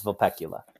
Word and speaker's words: Vulpecula: It is pronounced the same way as the constellation, which is Vulpecula.